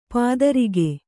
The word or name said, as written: ಪೈತೃಕ